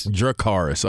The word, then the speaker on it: Dracarys: Chakaris, is that how you say it? – Mm-hmm. – How you spell it? – Dragonfire. D-R-A-C-A-R-Y-S. – Drakaris. Okay, there we go.